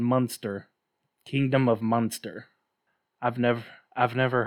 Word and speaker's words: Munster: No, it's pronounced like the cheese. – Transylvania. – Like Munster cheese. It's Munster.